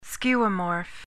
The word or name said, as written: skeuomorph